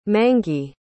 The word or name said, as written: mangey